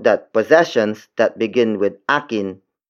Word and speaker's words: akin: Akin. Akin. Akin is spelled as A-K-I-N.